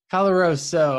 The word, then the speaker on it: Caloroso: Caloroso.